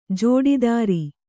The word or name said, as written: ಜೋಡಿದಾರ